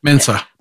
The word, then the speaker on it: Mensa: You're not going to become a Mensis by based on what Mensis, is it Mensis? – Mensa. – Mensa. Yeah. I knew I wasn't saying that right.